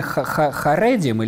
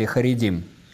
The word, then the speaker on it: харедим: Правильное ударение харедим или харедим? – Харедим.